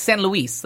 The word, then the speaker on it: San Luis: It's San Luis. Like, that's how it should be pronounced.